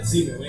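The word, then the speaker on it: Simmel: Zimmel, hein? Jorge Zimmel. E, se escreve com S. É, com S. Do, é, S-I-N-N-E-L.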